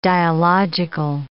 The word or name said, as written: dialogical